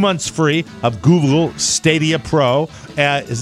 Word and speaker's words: Stadia: It is Stadia.